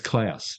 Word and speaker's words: Clowes: It's Klaus, not Clout. It's Klaus.